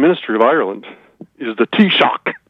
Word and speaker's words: Taoiseach: Taoiseach.